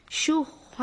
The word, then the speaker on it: شُحَّ: وَمَن يُوقَ شُحَّ نَفْسِهِ. – خب دقت بفهمید، در کلمه شُحَّ باید حاء جیمی از وسط حلق و با گرفتگی تلفظ بشه.